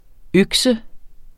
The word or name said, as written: økse